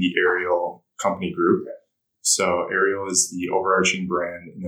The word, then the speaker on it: Aerial: So this is called The Aerial? Am I pronouncing that? – Correct. – Right? – Yeah, The Aerial.